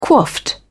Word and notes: coiffed: British English

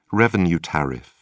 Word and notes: revenue tariff: British English

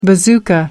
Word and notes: bazooka: American English

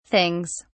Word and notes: things: British English

